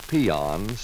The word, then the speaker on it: paean: In the dictionary, you will find a word pronounced peon, spelled P-A-E-A-N.